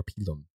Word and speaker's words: pilum: The plumbata and the pilum, or pilum, if you want to pronounce it like a Roman.